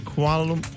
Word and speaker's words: Kuala Lumpur: Is it, Sarah, is it Kuala Lumpur? – Yeah, you said it right.